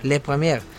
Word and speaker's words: Première: Esto lo sacamos desde un programa de la Première, ¿cómo se pronuncia? – Le Première,